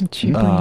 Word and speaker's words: chibi: Am I saying that right? – Yes. – Chibi.